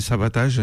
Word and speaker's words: Savatage: Donc là, c'est Savatage, parce qu'il faut prononcer Savatage, il paraît.